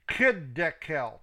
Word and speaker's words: Hiddekel: Now they have Hiddekel 2313 in the King James, but it is spelled C-H, it's a K sound. Kiddekel, C-H-I-D-D-E-Q-E-L. There's verses 5, and verses 5 and 6, you can reference Revelations 1, verses 13 through 15, Revelations 2, 18, Revelations 19, verse 12.